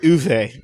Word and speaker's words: Uwe: UE Bull. – I love UE Bull. – It's pronounced Uwe.